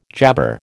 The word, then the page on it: jabber: American English